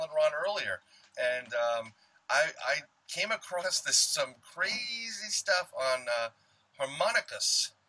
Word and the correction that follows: Homunculus: If I'm pronouncing that right again, Ron? – Homunculus. – Homunculus. I'm sorry.